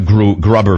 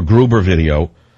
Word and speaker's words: Gruber: And we have other Grubber video. You can pronounce Gruber as Grubber, or Grubyan if you speak Jewish. Grubyan is another word. So here's a Grubber video, or Gruber video, where he boasts that the people were so stupid that Obama and he conspired how to screw the public on Obamacare.